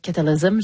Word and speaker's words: Cataclysm: Puis c'est une précision importante, je le disais à la française, mais ça doit être Cataclysm, j'imagine. Oui, c'est ça, je l'ai bien prononcé.